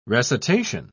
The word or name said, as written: recitation